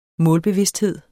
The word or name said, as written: målbevidsthed